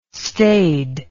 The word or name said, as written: stade